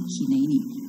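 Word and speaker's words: hineni (הִנֵּנִי): 以赛亚的反应是，我在这里，希伯来文就是 hineni，这个写法是正确的。刚才诗歌的那个写法是音译，讲它的发音 hineni，那这样的拼法是正确的一个拼法，大家一起说 hineni，所以大家都会希伯来文了。 hineni 呢是我在这里。我们看看在 hineni 在旧约的其他用法。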